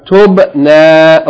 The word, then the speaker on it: tubna: Tubna. Okay, again, it's ta, dhamma, ba with sukoon. That's one syllable. Tub. The second syllable, noon, fatha, alif, yani elongation. Tub, na.